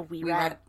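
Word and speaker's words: Wearat: The wee rat. – I was pronouncing it were rat, like a were rat. – But it's supposed to be a weasel rat. Oh, yeah, wee rat. A wee rat.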